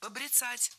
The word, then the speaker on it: побряцать: Побряцать неверно. Правильно, побрицать. Это слово имеет настолько непривычное ударение, что большинство людей не слышали и не представляют, что правильно побрицать, брицать.